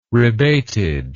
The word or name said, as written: rebated